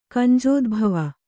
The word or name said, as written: ಕಂಜೋದ್ಭವ